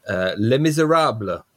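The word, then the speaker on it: Les Misérables: Last month, we said that we would be going to France and seeing Lajli's Les Miserables. You have to say it like that. – That was beautiful. Dead on.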